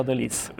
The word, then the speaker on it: de lis: No, de lis. – It's lis, so yeah. I need a Frenchman to learn that. I've been saying it wrong all the time.